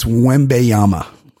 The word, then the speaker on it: Wembanyama: We have Victor Wayamba, the Stars new... – Very close. Pronounce his name again for me. – Wayamba. – Wayamba? I believe it's Wembanyama.